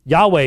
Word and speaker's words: Yahweh: Yahweh is the correct pronunciation. Jehovah is not even possible.